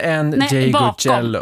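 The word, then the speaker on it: Ndegeocello: Michelle Ngeyoye. – Nej, Ngeyocello. Ngeyocello, ja.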